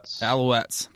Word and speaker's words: Alouettes: He was on the Hamilton Tiger Cats, but he got traded to the Montreal Alouettes.